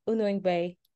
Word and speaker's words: Unuigbe: So before we go any further, Dr. Cynthia, will you pronounce your last name for us? Because I'm terrible at your last name. I feel badly about that. – All right, it's pronounced as Unuigbe.